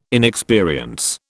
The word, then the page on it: inexperience: British English